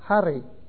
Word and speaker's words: Charis: Charis. Charis. Grace. The Greeks, the literal Greeks, it's a boy's name, and they pronounce it Harry.